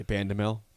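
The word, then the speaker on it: Bandoumel: Juwan Gary, Emmanuel Bandamel. Am I saying that right? – I would say Bandamel. – Bandamel, okay.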